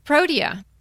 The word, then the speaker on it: protea: So it's seeded eucalyptus and it's protea. Did I pronounce that right? – Protea. Mm-hmm.